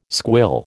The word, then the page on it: squill: American English